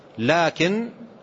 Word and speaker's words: لكن: قال لا، لكن بكسر الكاف وألف قبلها تنطق لكن للاستدراك، يؤتى بها للاستدراك، لا لكن أفضل الجهاد حج مبرور.